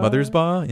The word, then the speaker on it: Mothersbaugh: Mothersbaugh? – Mothersbaugh, yeah.